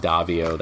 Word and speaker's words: Daviau: I am Rob Davio. That's a tricky last name. It's spelled D-A-V-I-A-U.